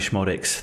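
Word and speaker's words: Szmodics: I think I've gone with Sammy Schmodix, the Schmod man. The Z is silent.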